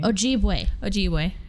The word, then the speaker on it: Ojibwe: Her name is Dunas Fontaine, and she is a biracial American 18-year-old with a white mom and an Ojibwe. Am I saying it wrong? – Maybe. – Ojibwe. – Yeah.